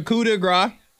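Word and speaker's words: coup de grâce: Yes, I was like, is that how you pronounce it? Yes. The coup de grace, it came out yesterday on HBO Max.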